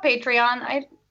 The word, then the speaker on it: Patreon: Is that how it's pronounced? Patreon? – Yeah, I call it Patreon.